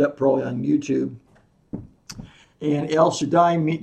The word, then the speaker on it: El Shaddai: In Hebrew, the Almighty God is pronounced El Shaddai.